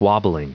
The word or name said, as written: wobbling